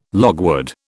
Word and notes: logwood: British English

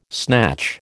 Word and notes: snatch: American English

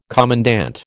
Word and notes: commandant: American English

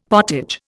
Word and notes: potage: Indian

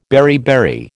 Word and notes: beriberi: American English

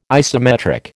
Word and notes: isometric: American English

